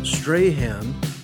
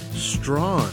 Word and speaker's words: Strahan: Here's New Zealand singer songwriter and a guy who I used to call Strahan, but recently learned the correct pronunciation is Strawn.